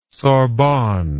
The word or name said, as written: sorbonne